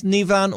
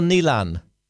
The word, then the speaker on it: Neilen: Are you is your name pronounced Nivan or Nealon? – Nealon. N-E-I-L-E-N. – N-E-I-L-E-N. Okay.